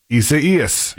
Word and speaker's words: Isaias: And they're saying on Florida Today, the National Hurricane Center tries to help with a pronunciation guide saying it should be announced Isaias.